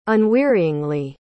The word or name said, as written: unwearyingly